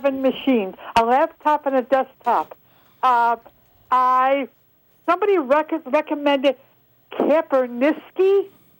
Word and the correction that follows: Kaspersky: Am I pronouncing for an internet security? Am I pronouncing it correctly? – Kaspersky. – Kaspersky.